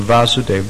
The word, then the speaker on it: Vasudeva: Okay, the position of Vasudeva. The stage is also called Vasudeva with a short A.